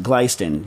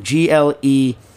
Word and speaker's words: Glestain: It's spelled Glestain, but I believe it's pronounced Glystain. Glystain.